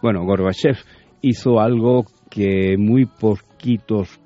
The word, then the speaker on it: Gorbachev: Digo Gorbachev porque en ruso se pronuncia Gorbachev, dirá alguien, es que Chávez ruso, bueno, sé algo.